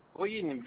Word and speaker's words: Boing: Boïne, comme Boïne s'écrit, B-O-I-N-G.